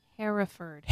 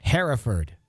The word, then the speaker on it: Hereford: Hereford. – Hereford. Hereford, England. – He has it. – I know. – Sound out for you. – Hereford. Sounds very, very manly. I live in Hereford.